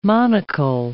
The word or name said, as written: monocle